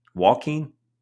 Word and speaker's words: Joaquim: J-O-A. Q-U-I-M? Jaquim? I don't even know what that says. – Joaquin. – That's a hot name. – Like Joaquin Phoenix? – I bet Tom Brady's like, this motherfucker.